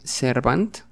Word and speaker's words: servant: Esta se escribe servant, pero se pronuncia... la última, la A, se pronuncia como si fuera una E. Servant, servant, servant.